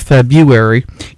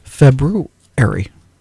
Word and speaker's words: February: And, okay, the acceptance of February irritates fans of the traditional standard. – Now, what is the traditional? – That's how I pronounce it, February. – Say that again. – That's most traditional.